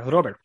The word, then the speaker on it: Robert: It's hard to, you can't really say it with an American accent. You have to say it while you're rolling the R, Robert. – Sure. – And yeah, it's hard to say in flow. So I think Robert is the closest thing that we're going to get to how he actually pronounces it himself.